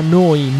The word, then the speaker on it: Neu: Si scrive Neu, si pronuncia noi, vuol dire nuova emittente urbana da Bologna, 24 ore su 24, 7 giorni su 7.